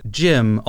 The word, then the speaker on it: GM: And then the J, I think, is a shortening for like James, whereas the Jim, although it's spelled GM, is pronounced like the name Jim, which again is short for James.